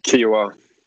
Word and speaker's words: Kiawah: So we're here this week, the PGA Championship at Ocean Course and how do you say that, Kiawah Island? – Yep, Kiawah.